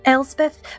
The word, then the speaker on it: Elsbeth: So, Elspeth, I'm saying that correctly, right? – Elspeth.